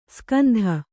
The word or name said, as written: ಸುಗಂಧ